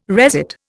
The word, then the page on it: resit: Indian